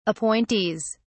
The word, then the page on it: appointees: British English